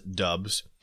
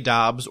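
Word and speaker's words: Doubs: There's a Nevada reporter who has a tweet out from a while ago explaining that you pronounce his name as Dubs.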